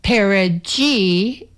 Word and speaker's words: perigee: And so perigee is the correct answer. Perigee is the sound of pair of jeans.